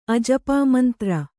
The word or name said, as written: ಅಜಪಾಮಂತ್ರ